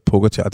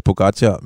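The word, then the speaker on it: Pogačar: Altså, jeg har lyst til at lige opspille et lille klip, fordi jeg har fandme fået meget røg for, at jeg siger Pogacar. Og jeg ved godt, at han udtales Pogacar, men jeg kan simpelthen ikke lade være.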